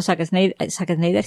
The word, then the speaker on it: Zack Snyder: Estábamos comentando aquí al muchacho Zack Snyder, si lo he dicho bien.